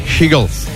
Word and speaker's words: Hegel: Alors, faut bien prononcer Hegel. Parce que si tu dis Eagle, il tient rien. J'ai l'impression que tu parles un mec qui parle fort.